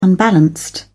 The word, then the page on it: unbalanced: British English